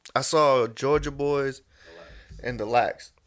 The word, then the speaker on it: Jawga: They spell it J-A-W-G-A. Georgia. But it's Georgia Boys.